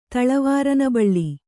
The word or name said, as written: ತಳವಾರನಬಳ್ಳಿ